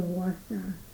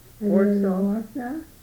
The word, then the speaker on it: Wausau: Of Warsaw. Warsaw? Well, it's a world-famous insurance company. It's changing, and they call it Warsaw Story. And so that became the company. – How do you spell that? – W-A-U-S-A-U.